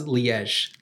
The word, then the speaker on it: Liège: Oh, that's Liège.